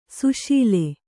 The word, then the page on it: ಸುಸಿಲು: susilu